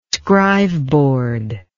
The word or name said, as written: scriveboard